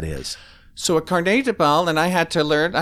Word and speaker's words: Carnet de Bal: And tell me if I'm saying this correctly or otherwise. It's called a, uh, a Carnet de Bal.